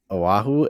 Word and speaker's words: Oahu: Oahu in Hawaii. – Oahu.